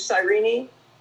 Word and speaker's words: Cyrene: I, I, I, I always use the English pronunciation, which is Cyrene.